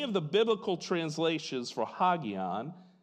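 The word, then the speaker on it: hagion (ἅγιον): The one I'm interested in is pronounced hagion. Say hagion. Good job.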